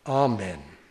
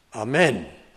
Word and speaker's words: amen: And incidentally, in the word amen, this is English grammar, not spiritual teaching, but the word amen in the English language, the emphasis is on the first syllable, amen. If you put it on the second syllable, amen,